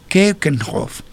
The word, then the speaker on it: Keukenhof: Eh, ¿Dónde queda Keukenkopf? Repito, Keukenhof. Keukenhof se escribe K-E-U-K-E-N de Natalia H-O-F.